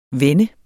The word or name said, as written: VENDES